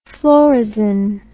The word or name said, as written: phlorrhizin